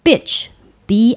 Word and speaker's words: bitch: 那大家在用这个字的时候，如果你真的要用的时候，你一定要注意一件事情，就是你的发音一定要正确，因为大家常会把这个字跟海滩搞错。好，就是 bitch，b i t c h，bitch，就是一个有点短促的音，一个急促的音。